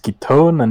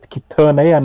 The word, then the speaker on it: Ketone: Since the game is voice acted, we know it's pronounced Ketone and not Ketone, and I'm endlessly annoyed by it.